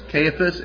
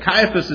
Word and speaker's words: Caiaphas: Now, Caiaphas is pronounced different ways. It can be pronounced Caiaphas, and I've even heard it pronounced Caiaphas. And so, but Caiaphas is the way I learned it growing up. So that's how I'm going to say it today.